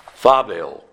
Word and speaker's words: phobeo: You know, the Greek word for fear is phobeo. It's spelled P-H-O-B-E-O, phobeo.